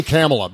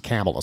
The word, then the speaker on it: Kamala: But Rod Rosenstein was a real asshole to especially Kamala Harris, or is it Kamala? I say Kamala because I know someone else who's a feminist activist whose first name is Kamala. She pronounces it Kamala, so I pronounce it that way.